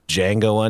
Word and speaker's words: Django: is there another beer called Django Unchained or Mango Unchained with a silent right.